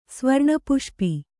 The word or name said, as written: ಸ್ವರ್ಣಪುಷ್ಪಿ